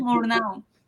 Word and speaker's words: Murnau: Eh, em, esta fue dirigida por, ay Dios, estos nombres de esta gente, del otro lado, eh, Friedrich Wilhelm Murnau Munau. Aquí como, por favor, producción, ¿cómo se pronuncia esta vara? Friedrich Wilhelm Murnau.